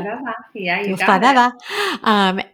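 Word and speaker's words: Girls5eva: Is that how you say it? – Girls 5 Eva.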